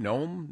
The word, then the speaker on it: Noem: Is that how I say her name? Noem? – Yeah, Kristi Noem, yeah.